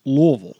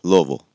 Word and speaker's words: Louisville: Because if you're going to say it right, it's Louisville. – Louisville. – It is Louisville.